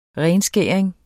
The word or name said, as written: renskæring